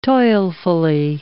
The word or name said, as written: toilfully